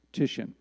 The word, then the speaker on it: Titian: That is a self-portrait of a Renaissance painter named Tiziano Vecellio, better known as Titian. Looks like Titian, it's pronounced Titian.